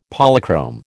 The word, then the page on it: Polychrome: American English